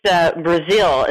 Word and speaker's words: Breazeale: Okay, it's Breazeale. It's pronounced like the country, but certainly not spelled that way.